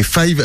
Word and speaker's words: Fayv: Après, il a une musique très marquée, très à lui, qui ressemble pour moi à personne, et c'est du Five, en fait. – Et Five, alors, juste un truc, ça s'écrit pas comme Five, le chiffre 5. – Non, voilà, là, F-A-Y-V.